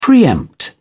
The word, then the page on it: pre-empt: British English